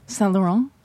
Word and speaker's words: Saint Laurent: Saint Laurent.